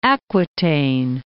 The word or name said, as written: Aquitaine